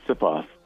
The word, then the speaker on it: Sipos: First of all, I want to get the proper pronunciation. Aaron Sipos? – I think it's Sipos. But I'm Sipos. Yes. The Australian version.